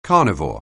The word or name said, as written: carnivore